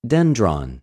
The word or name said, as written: δενδρον